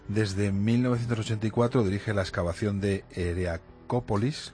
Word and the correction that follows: Heracleópolis: lo he dicho bien. – Heracleópolis. – Heracleópolis, Jesús.